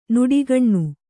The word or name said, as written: ನುಡಿಗಣ್ಣು